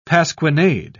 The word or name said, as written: pasquinade